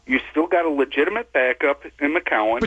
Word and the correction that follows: McCown: Why do you, McCown! It's not McCowan!